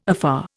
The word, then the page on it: afar: Indian